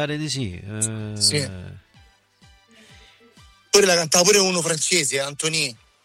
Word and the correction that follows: Antoine: Antonini. – Forse Antoine. – Antoine, scusa. Che pronuncia che ciò? Antoine, sì. – Antoine, non Antonio.